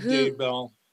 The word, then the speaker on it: Daybell: Chad Daybell and Lori Daybell.